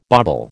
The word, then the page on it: bobble: American English